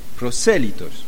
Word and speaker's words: prosélitos: Y también había proselitos, hombres que no eran judíos, pero que escuchaban el Evangelio. Prosélitos, sí. Sí, pronunciación de español y no del francés.